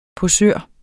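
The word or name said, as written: POSØREN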